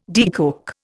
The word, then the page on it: decoke: Indian